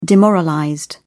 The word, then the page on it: demoralized: British English